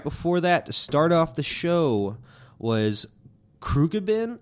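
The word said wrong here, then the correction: Khruangbin: How do you say that? – Krongbin. – Kromden? – Krong. – Krom. – Bin. – Bin. – K-H-U... – No, K-H-R... U-N-G-B-I-N. Krongbin.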